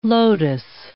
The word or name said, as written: lotus